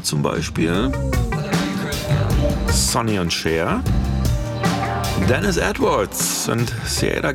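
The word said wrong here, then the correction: Siedah: Garrett. Moment, stopp. Zurückgespult. Saida Garrett, habe ich neulich gelernt. Ich habe es auch immer falsch ausgesprochen.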